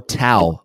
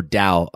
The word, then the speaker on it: Dao: Now, you mentioned before Tao or Dao, I think it's pronounced Dao.